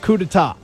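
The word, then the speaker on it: coup d'état: Now, did Mike Leach make you Google the definition of coup d'etat? I did pronounce that right, didn't I? – You did.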